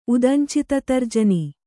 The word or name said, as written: ಉದಂಚಿತತರ್ಜನಿ